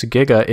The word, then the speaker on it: giga: Yeah, so this is saying, in fact, it addresses this specifically in the Wikipedia article, where it says, the prefix giga is usually pronounced in English with a hard G, as in get, but sometimes with a soft G, as in gin.